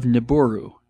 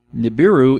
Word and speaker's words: Nibiru: In the pseudoscience corner, I don't even have any idea how I came across this, but I came across the planet of Nibiru, I think is how it's pronounced, N-I-B-I-R-U, Nibiru.